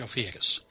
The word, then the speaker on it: Ophiuchus: So last week, our last podcast, we talked about the 13th sign, Ophiuchus, which apparently in the meantime I found out actually how to pronounce is, in fact, Ophiuchus.